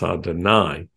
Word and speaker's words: Adonai: I appeared to Avraham, Yitzchak, and Yaakov as El Shaddai, although I did not make myself known to them by my name, Yod-Heh-Vav-Heh, which we pronounce Adonai.